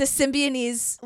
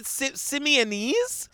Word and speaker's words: Symbionese: The Symbionese Liberation Army. – I don't think there's a B in there. Is it Symbionese? – Symbionese. – There's a B? – There's a B, girl.